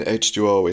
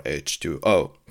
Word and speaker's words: H20: It's pronounced in English usually H2O. As my understanding goes, the H stands for age, as in how old are you? So Halloween is 20 at this point, so thus H20 or H2O.